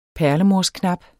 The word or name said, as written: perlemorsknap